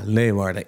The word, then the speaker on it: Leeuwarden: Zeg ik het goed? Ja, Leeuwarden.